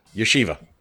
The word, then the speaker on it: yeshiva: Yeshiva.